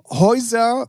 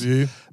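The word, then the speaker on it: Hozier: Dann Häuser oder Hosier, ich weiß nicht, wie er ausgesprochen wird, Häuser, glaube ich, Hosier.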